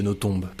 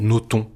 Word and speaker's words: Nothomb: J'ai bien prononcé le B de Nothomb, parce que parfois, certains journalistes vous appellent Nothomb. – Tout le monde fait la faute. Or, le B se prononce, donc je suis très sensible au fait que vous l'ayez prononcé.